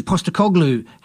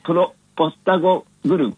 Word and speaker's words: Postecoglou: Ozzie Ange Postecoglou.